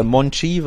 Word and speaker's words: Monchi: Ich meine, ich habe ihn im letzten Podcast immer falsch ausgesprochen. Ich habe immer den Barca B-Spieler genannt, ne, muss ich mich dafür entschuldigen. Also, Monchi,